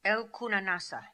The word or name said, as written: El Koona Nassa